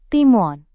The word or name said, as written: timun